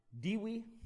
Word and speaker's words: divi: And written on the front, the obverse, tikes are divi, and the V is pronounced as W, aug faug guestwes.